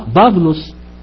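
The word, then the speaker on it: باولوس (Παῦλος): باولوس تتنطق كمان باولوس في النطق القديم لان الالفا يبسط تتنطق او تمام.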